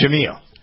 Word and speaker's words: Jemele: It's Jamil, I believe, isn't it? – It's Jamil. Okay.